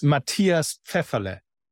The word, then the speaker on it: Matthias Pfefferle: It's Matthias Pfefferle.